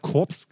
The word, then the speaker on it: Korps: Also doch Korps geschrieben. Richtig. – Aber warum wo ist das Französisch? Warum spricht man denn das Korps nicht mit? In Deutschland spricht man doch jedes, jeden Buchstaben.